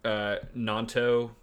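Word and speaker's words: Gnonto: But Willie Nanto with the silent G in the front, I don't know.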